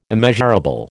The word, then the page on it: immeasurable: American English